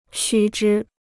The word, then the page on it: 须知: xū zhī